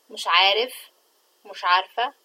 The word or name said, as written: mish3aarfa